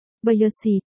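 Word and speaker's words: Bây Sì (褒姒): Một, Đát Kỷ đọc là, Giá Di, Bao Tự đọc là, Bây Sì, Tô Hy đọc là, Su Si, nhưng âm, Sì, trong, Bao Tự, đọc hơi khác một chút.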